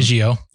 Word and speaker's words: Biggio: Biggio.